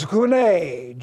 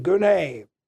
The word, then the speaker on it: gune: Now, the word for woman is gune, G-U-N long E, gune, which covers some 6 columns and spans 4 pages.